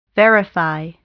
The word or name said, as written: verify